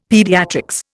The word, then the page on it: Paediatrics: Indian